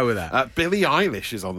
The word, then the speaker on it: Eilish: Is, I mean, I, I think how, Eilish.